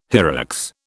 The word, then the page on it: hyrax: British English